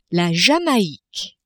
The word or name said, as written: Jamaique